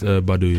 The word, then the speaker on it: Badu: It reminded me of Erykah Badu, I think that's how you say her name. – That is Badu, yeah.